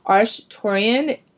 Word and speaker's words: Arcturian: It's Arcturian, as everyone knows it, but they say that Arcturian, and that's actually in the last chapter of my book, how that came to me, is the correct pronunciation